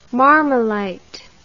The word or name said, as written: marmolite